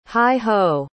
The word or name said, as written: heigh-ho